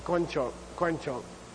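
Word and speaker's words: koin chok (དཀོན་མཆོག): If you're studying Tibetan, it's not pronounced the way it should be. It's koin. Okay, koin chok. It's unusual, okay? It's an exception.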